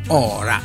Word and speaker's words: Ora: No, si chiama tuttora si chiama appunto Minna Ora, ma con l'accento sopra, Ora.